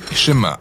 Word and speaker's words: Shema: Le premier mot du Shema est écouter ou entendre, prononcé Shema en hébreu.